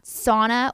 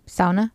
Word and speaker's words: sauna: Okay, now the first time you pronounced it sauna. – And I'm just covering all the bases. – Well, no. I only ask because I thought mm-hmm. you were one of the few people who actually knew how to pronounce sauna. – Sauna. Most people just say sauna.